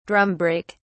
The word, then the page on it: drum brake: British English